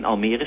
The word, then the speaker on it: Almere: Maar in elk geval, zoals het geschreven staat, moet je het uitspreken als Almere. – En om alle misverstanden dan maar kort te sluiten, we zitten ook niet in Almere stad.